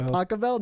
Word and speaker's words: Pachelbel: Is that how you say it? Pachelbel, not Pachelbel? – It's Pachelbel.